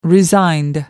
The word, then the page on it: resigned: American English